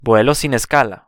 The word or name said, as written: vuelo sin escala